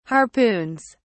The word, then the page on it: harpoons: British English